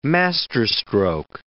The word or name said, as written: masterstroke